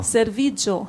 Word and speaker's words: servigio: Serviglio. – Serviglio. – No, servigio, sorry.